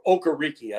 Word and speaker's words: Okereke: Okereke, I guess, is the correct pronunciation of the game,